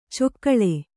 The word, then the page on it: ಚೊಕ್ಕಳೆ: cokkaḷa